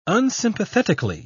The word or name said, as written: unsympathetically